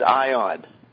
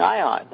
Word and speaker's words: Ayan: his name is Ion. It's spelled A-Y-A-N, but they keep saying Ion. That's how they pronounce it.